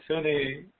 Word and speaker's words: Phinney: Yeah, it's Finney. Hold on, I got it here. Spell it for you. P-H-I-N-N-E-Y.